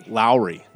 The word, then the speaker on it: Lowry: Lowry, okay. – Yeah, rhymes with dowry.